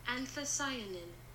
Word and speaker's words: anthocyanin: And then this next one, which I believe it's pronounced anthocyan. – Anthocyanin. – Dramatic pause. But that's how you pronounce it.